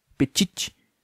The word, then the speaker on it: Petit: Petit. Mas aí o T é mudo, porque ele é uma bichona francesa.